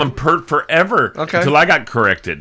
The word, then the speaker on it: Peart: Why do you call him Peart? – Because that's how he pronounces his name.